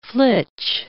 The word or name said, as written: flitch